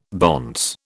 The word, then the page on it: bonce: British English